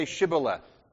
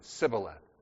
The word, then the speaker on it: Shibboleth: And the Ephraimites couldn't pronounce Shibboleth. They pronounced it Sibboleth. It's part of their kind of family, tribal custom and culture, the way they pronounced words. They couldn't pronounce Shibboleth. They said it's Sibboleth.